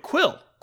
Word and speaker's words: Kuiil: K-U-I-I-L. It's how you spell it. – Quill. Quill. That's Quill. – His name.